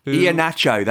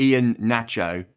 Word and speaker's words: Iheanacho: Iheanacho, that's right, James. Iheanacho, not Ian Nacho, not Iheanacho, Iheanacho.